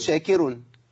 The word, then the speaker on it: شاكر: ومن تطوع خيرا فان الله شاكر، خد بالك شاكر ما تنفعش، ولا شاكر ما تنفعش. الكاف نطقها كاف، لا تنطق قاف، شاكر ما تنفعش، ولا خلي الراء مرققة اقول شاكر، هي شاكر عليم سبحانه وتعالى.